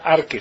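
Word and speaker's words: arque (ἀρχή): Y es importante, la palabra que se utiliza es arque. Aquí ven la palabra, arche, ¿verdad? Arque, se pronuncia arque.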